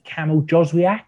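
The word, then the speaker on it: Jóźwiak: If I look at someone like, is it Kamal Joswiak? Is that how you pronounce his name? – Yeah.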